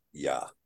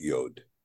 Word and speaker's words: Yod: I can tell you that we're told by the Masoretes that this letter here is Yod. But there are many in the camp who now believe that this letter is correctly pronounced Yah, not Yod.